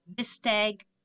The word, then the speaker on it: Vistiq: If you're looking to grow your aura, consider hitching your wagon to the Cadillac Vistag Electric SUV. It's spelled V-I-S-T-I-Q.